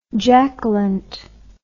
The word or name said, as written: jackalent